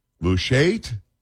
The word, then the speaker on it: Mushait: I don't know how to say this, M-U-S-H-A-I-T, Mushait? Can we say, we're going to go with that?